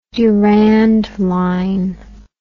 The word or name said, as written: durand line